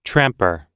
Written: tramper

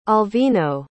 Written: alvino

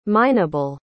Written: mineable

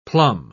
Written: plumb

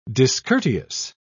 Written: discourteous

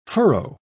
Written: furrow

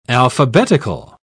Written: alphabetical

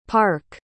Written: parc

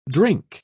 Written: drink

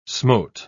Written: smote

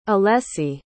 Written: alessi

